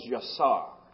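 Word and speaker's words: yasar (יצר): In the Hebrew, it's a word we pronounce as yasar. Y-A-S-A-R.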